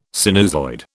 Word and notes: sinusoid: British English

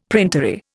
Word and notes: printery: Indian

